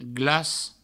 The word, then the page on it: glac: glas